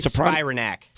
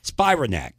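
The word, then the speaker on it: Spiranac: I called it Sopranic. – I would say Spironak. – Spironak.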